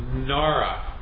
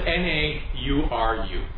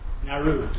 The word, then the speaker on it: Nauru: NARA. Is that how you pronounce it? N A U R U. Nauru. Nauru.